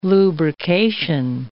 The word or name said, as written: lubrication